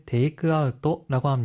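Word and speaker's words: 테이크아웃토라고 (テイクアウト): 하이, 아웃은 일본어로 아웃토라고 발음하기 때문에 테이크아웃은 테이크아웃토라고 합니다.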